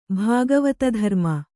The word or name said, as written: ಭಾಗವತಧರ್ಮ